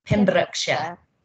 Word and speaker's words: Pembrokeshire: Pembrokeshire.